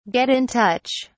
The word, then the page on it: get in touch: British English